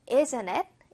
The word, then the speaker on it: isn't it: 자, 근데 우리 원어민 성우 선생님이 예센체이라고 친절하게 발음을 해주셨는데, 원어민들 중에서는 예센엣 이런 식으로 T를 살짝 무음화시키는 경향도 있습니다. 무음 버전도 좀 해볼게요. Isn't it?